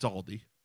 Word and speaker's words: Aldi: So, it's Aldi. – Aldi? – Aldi. Thank you.